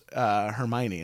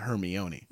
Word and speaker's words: Hermione: But when I first saw the first movie, it was when I found out that it's Hermione and not Hermione.